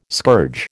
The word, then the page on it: scourge: American English